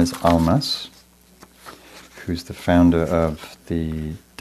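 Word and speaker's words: Almaas: So his book name is Almas, A-L-M-A-A-S, A-H Almas, but he's often, was also called Hamid, and founder of the Diamond Approach work.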